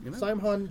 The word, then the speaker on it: Samhain: Samhain, how do you pronounce it? – Samhain jet bike.